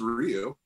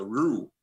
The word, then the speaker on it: Ryu: Actually pronounce his name properly. The Ru, you know, there's like this roll of the tongue that I can't do it.